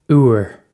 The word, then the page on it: úr: Irish